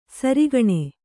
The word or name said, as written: ಸೆರೆಗಣ್ಣು